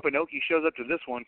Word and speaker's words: Inoki: And then today they had a press conference to put in, how do you say his last name, Inoki? Is that right? – Oh, yeah, Antonio Inoki. Okay.